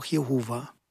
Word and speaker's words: Jahwe: Diejenigen, die wie im hebräischen Text den Eigennamen Gottes von den Titeln Gott und Herr unterscheiden, fügen traditionell die Vokale A und E oder auch E, O und A hinzu. Und so kommt man dann zu der Aussprache Jahwe oder Jahwe oder auch Jehova.